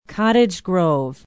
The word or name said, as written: Cottage Grove